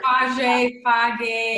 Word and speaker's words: Fage: Um, and then um, I use Fage. I think, am I pronouncing that right? fat-free Greek yogurt. – Fage, fage, yeah.